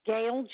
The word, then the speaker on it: Gail: Gail, G-A-I-L, and my last name is spelled R-A-M like Mary, E-E.